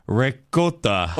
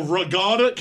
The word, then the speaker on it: ricotta: Because I start to say ricotta. – Oh, ragata? Can I have the pecan ragata cheese? – I didn't say regatta. – Regatta? – Ricotta.